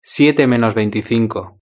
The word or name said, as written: siete menos veinticinco